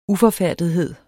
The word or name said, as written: uforfærdethed